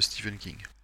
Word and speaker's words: Stephen King: Donc non, non, c'est une bonne introduction, on va dire en douceur à l'univers de, Stephen King.